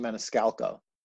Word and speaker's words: Maniscalco: Sebastian Maniscalco.